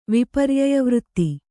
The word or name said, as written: ವಿಪರ್ಯಯವೃತ್ತಿ